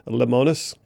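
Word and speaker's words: Lemonis: And Camping World is owned and run by a guy by the name of Marcus, and I'm probably doing a disservice to his last name, but I believe it's pronounced Limonis.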